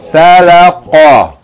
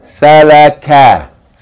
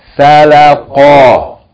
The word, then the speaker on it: salaqa: Salaqa. – Salaqa. If it was a calf, how would you pronounce it? Salaka. Salaka. But this is what? Salaqa. Huh? Ka. Qa. Salaqa.